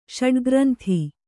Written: ಷಡ್ಗ್ರಂಥ

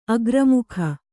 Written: ಅಗ್ರಮುಖ

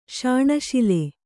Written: ಶಾಣಶಿಲೆ

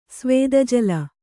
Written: ಸ್ವೇದಜಲ

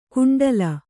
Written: ಗುಂಡಲ

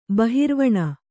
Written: ಬಹಿರ್ವಣ

